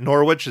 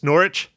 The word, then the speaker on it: Norwich: Didn't they always correct people that it wasn't Norwich, it's Norwich? – Yeah, I think so. That's why I said it wrong. That's why I said it wrong as well. – It's so funny. How the hell do you have a silent W? – I don't know.